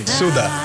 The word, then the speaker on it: Sude: Suda. Suda. – Suda. – Se escribe Sude, pero se llama. Se pronuncia Suda.